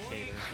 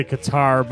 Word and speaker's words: Qatar: I think it's Cater. That's how you're supposed to pronounce it. – The news stations always say Qatar, but when they were getting... the World Cup, all of a sudden it was, no, it's Cater, and it's like, oh, okay, cool.